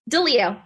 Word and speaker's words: DiLeo: DiLeo.